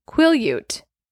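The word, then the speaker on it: Quileute: It says, Jacob has cut his long hair and now has the same quillette. Nope, I said it wrong again. – Quillute tattoo on his upper right arm as Sam and the other tribe members.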